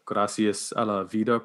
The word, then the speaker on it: Gracias a la Vida: So, you know, with that said, can you tell me about the Gracias a la Vida project? – Yeah, definitely.